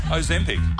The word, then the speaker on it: Ozempic: Skipped it because they're on a Zimpic. – A Zimpic? – How do you say it? – Ozempic.